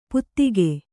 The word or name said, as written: ಪುತ್ತಿಕೆ